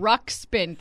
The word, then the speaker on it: Ruxpin: Ruxpin.